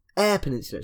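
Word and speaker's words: Eyre Peninsula: Air Peninsula. It's pronounced Air Peninsula because that was a correction like a year ago.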